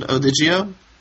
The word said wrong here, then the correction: Odigo: Odigo, yeah. – Odigo, okay.